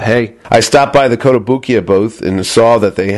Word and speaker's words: Kotobukiya: Nakamura, and it's Kotobukiya. I never say it right. – Kotobukiya, yes, that's correct.